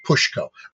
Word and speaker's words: Ptushko: It's Ptushko. I'm guessing the T is silent because otherwise it'd be Petushko and you'd be spitting saliva out of your mouth.